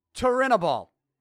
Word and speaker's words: Turinabol: Here's my best job trying to pronounce this. Turinabol. T-U-R-I-N-A-B-O-L. Turinabol. So that's how we're going to pronounce it.